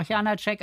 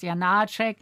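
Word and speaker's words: Janáček: würde auch sagen, aber das, wie gesagt, mit, man sagt ja auch Janacek, aber es heißt Janacek. Also da ist auch die Betonung auf.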